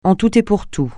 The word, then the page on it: en tout et pour tout: français